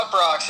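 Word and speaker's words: Uproxx: Yeah, UpRocks is.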